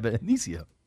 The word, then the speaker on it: Benicio: Isn't it Benicio? – Yeah, Benicio.